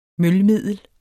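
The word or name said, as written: mølmiddel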